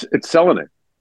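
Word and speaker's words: Selänne: What's interesting is that had he come over later, we've said Teemu Selanne's name wrong for 30 years. It's Selanne. – Is it? – Yeah. In Finnish, the first syllable is always accented.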